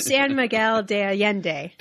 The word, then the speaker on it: San Miguel de Allende: How am I saying it right? – Now you're going to mess me up. San Miguel de Allende. – De Allende. That's right. Exactly.